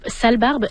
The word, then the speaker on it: Sale Barbe: Et en terminant, on a un nouvel album pour le groupe Sale Barbe. Est-ce que c'est bien comme ça qu'on prononce? – C'est bien comme ça qu'on prononce.